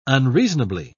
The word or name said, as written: unreasonably